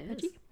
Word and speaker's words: Burggy: Several days later, though, on the 16th of January, farm worker Fred Burgy. It's spelled B-U-R-G-G-Y. – Okay. – I was just like, that's strange. Burgy? – Yeah, Burgy.